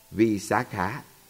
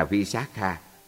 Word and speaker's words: Visakha: Nếu như người ta là một phụ nữ, tên họ sẽ được phát âm là Visakha với âm Á dài. Còn nếu là người nam, tên họ được phát âm là Visakha với âm A ngắn.